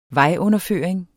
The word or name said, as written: vejunderføring